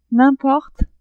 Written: n'importe

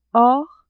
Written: hors

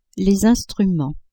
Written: instruments

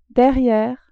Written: derriere

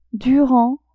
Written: durant

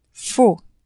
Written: faux

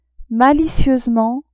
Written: malicieusement

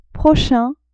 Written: prochain

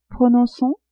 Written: Prononcons !

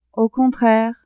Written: au contraire

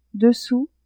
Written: dessous

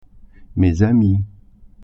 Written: amies

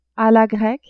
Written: a la grecque

